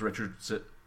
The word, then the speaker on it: Richards: Guys, it's time we wrap back around to KEXP DJ Reeves Richardson.